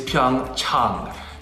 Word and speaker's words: Pyeongchang: The correct pronunciation is Pyeongchang. Chang, ah, ah, like when you go see your doctor, ah. Pyeongchang, Pyeongchang. – Pyeongchang. – Ah. – Pyeongchang. – Ah. – So that's right, Pyeongchang. Yeah. Everybody's saying it wrong at NBC.